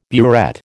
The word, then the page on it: burette: American English